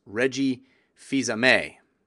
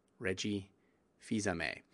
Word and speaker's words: Reggie Fils-Aimé: I was kind of doing it tongue-in-cheek, but yes, it is pronounced Reggie Fils-Aimé. Reggie Fils-Aimé.